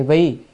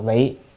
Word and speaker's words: vị: Thì đằng sau nói đản vị tương lai chúng sanh, chữ đản là chỉ, chữ này là chữ vị hay vi, ở đây phải đọc là vị, chỉ vì chúng sanh ở đời tương lai.